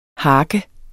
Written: hark

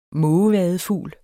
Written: mågevadefugl

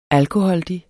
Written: alkoholdig

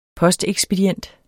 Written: postekspedient

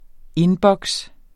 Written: indboks

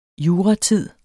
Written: juratid

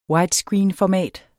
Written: widescreenformat

